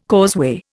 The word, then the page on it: causeway: Indian